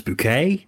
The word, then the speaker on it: Bucket: It's a show that revolves around a woman called Hyacinth Bucket, who insists that her surname is actually pronounced Bouquet, who is always trying to, she's just getting us.